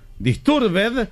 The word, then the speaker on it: Disturbed: No hay ninguna palabra en inglés que al final se pronuncie ed. Disturbed se pronuncia disturb.